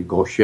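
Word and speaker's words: Gautier: We have a second home in Mississippi at a little town called Goche. The French pronunciation would be Goche, I guess.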